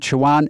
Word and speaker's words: Siobhan: S-I-O-B-H-A-N. – Oh, Siobhan. – Oh, it's Siobhan. It's not Siobhan. It's Siobhan.